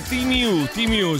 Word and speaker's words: Temu: Bravissimi, Temu è Timu, si pronuncia così.